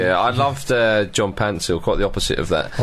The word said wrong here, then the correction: Paintsil: Pansil's not even his name. His name is Paintsil.